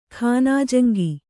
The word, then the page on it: ಖಾನಾಜಂಗಿ: khānājaŋgi